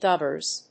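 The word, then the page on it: dubbers: /ˈdʌbəz/; Received Pronunciation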